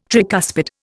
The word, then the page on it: tricuspid: Indian